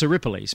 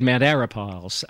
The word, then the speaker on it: Arapiles: What was that pronounced as? – Well, it's Aripiles, Mount Aripiles. – That's right. – but silly Gary said Mount Arapiles.